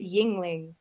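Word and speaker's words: Yuengling: And explaining like you pronounce it Yingling.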